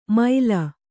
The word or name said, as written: ಮೈಲ